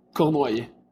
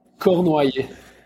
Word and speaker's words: Cournoyer: So it's Samuel Cournoyer. – Samuel. say your last name again. – Cournoyer. – Corn Cournoyer. Samuel. – So, for, like in English, just Cournoyer. – Cournoyer. – Yeah, that's good.